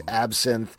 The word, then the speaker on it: absinthe: Based on your book, it is rye whiskey, amaro, honey syrup, lemon juice, absinthe, Angostura bitters. Am I mispronouncing that? – No, that's right.